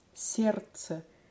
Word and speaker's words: Сердце: the word for heart, there's actually sounds that we don't pronounce. – Сердце. – Genau, das D, was geschrieben ist, wird nicht ausgesprochen. Definitiv nicht. Exactly. So the D in the middle of that word is just completely ignored.